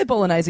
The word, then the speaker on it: bolognese: Just going back, can you say bolognese again? – No. – What do you got? Do you say bognaise? – I think I say, I know I say it wrong. – You pronounce the G, which I like.